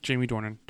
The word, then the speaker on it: Jamie Dornan: I know, but I just feel, maybe I just don't, no offense to Jamie Dornan, or how you pronounce it? Jamie Dornan.